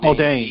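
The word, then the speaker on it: Paul Dehn: that's Paul Dean or Dane, I'm not sure the pronunciation of his name. – Paul Dane, yes, that's right.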